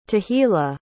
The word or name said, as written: tehila